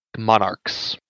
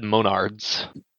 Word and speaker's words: monarchs: Monarchs. – What did I say? – You said monards.